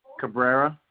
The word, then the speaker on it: Cabrera: Cabrera. – Spell it. – C-A-B-R-E-R-A. – C-A-B-R-A-R-A. – C-A-B-R-E-R-A.